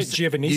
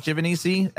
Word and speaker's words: Giovannisi: There's so many different ways to pronounce it that I've stuck with Giovannisi as the way that I would like to pronounce it. Cause it's, I guess, but there's other like more accurate ways to say it, but, is that Italian?